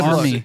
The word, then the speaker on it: Armie: So you want to have something in common with Arnie Hammer? – Yeah. – Not a good Army. Army, by the way. – Don't care. – You said Arnie.